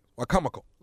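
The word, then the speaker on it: Wicomico: No, it's Wacomico. – Oh, okay, Wacomico. – No, it's not Wacovia. It's not Wacovia. Wacomico.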